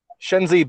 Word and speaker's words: Shenzi: And then we had Senzi Ben, I can't ever pronounce their names. – Shenzi, Banzai, and Ed. – Yes, thank you.